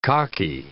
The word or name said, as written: cocky